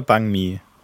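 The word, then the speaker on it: bánh mì: Es ist fucking Bang Mi. Bei, ah. Mi. Es wird geschrieben. Was jetzt bei Mi oder Bang Mi? B-A-N-H-M-I. Und das ist. Ja, aber wird anscheinend mit einem G ausgesprochen.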